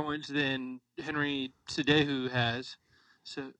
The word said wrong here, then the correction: Cejudo: Cejudo, sorry, I said that wrong.